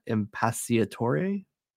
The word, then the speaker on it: Impacciatore: and then Sabrina, I'm not gonna say this right, but Sabrina Impacciatore.